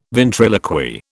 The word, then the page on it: ventriloquy: British English